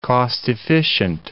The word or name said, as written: cost-efficient